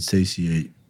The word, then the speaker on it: satiate: Nothing can satiate. Did I say that right? Satiate. – Yeah. Good job.